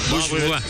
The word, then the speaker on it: Bourgeois: Que se escribía Bourgeois. Ya cuando supimos que tenía lo de la pronunciación francesa, era totalmente diferente.